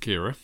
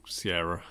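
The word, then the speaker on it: Ciara: So, in this country, we call it Kira. I'm going to say it's Irish. And that way, I don't know if that's true, but it sounds Irish, and they pronounce things weirdly. An American friend of mine said they pronounce it Sierra.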